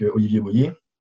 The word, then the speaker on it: Olivier Boyer: C'est ça, et je suppose que tu en as parlé aussi avec Olivier Boyer. C'est comme ça que ça se prononce, hein, C'est ça, ouais.